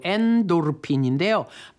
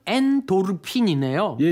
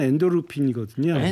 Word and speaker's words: 엔도르핀: 몸 안에서 나오는 모르핀 같은 천연 진통 시스템이 바로 엔돌핀인데요. 발음이 엔돌핀인 줄 알았는데 정확한 발음은 엔도르핀이네요. – 예, 예. 발음은 보통 우리가 엔돌핀 그러는데요. – 네. – 예. 의학적은 엔도르핀이거든요.